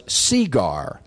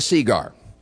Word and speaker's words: segar: and it should be noted here that the proper Virginia pronunciation was cigar, spelt S-E-G-A-R, emphasis on the E, as in, "Orderly, hand me a cigar."